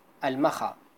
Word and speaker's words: Al-Makha: So, and yeah, anyways, mocha coffee, it's named after there's a city in my own country in Yemen with the name Al-Makha, if you pronounce the word in Arabic, the word is Al-Makha.